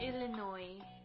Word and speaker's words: Illinois: Illinois? – The S is silent, I believe. – Illinois.